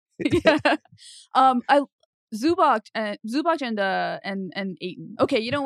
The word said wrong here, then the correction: Zubac: Zubats. I love calling him Zubac. As you know, I have pronunciation dyslexia, but it is Zubats.